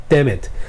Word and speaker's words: damn it: 但是 daggone it 这个意思其实就是它是一个比较温和的讲法，去讲 God damn it，就是妈的 God damn it。你在讲 D A M N 的时候，那个 damn damn 最后那个 M 不发音，所以你要把 m 跟 it 连起来，所以是 damn it。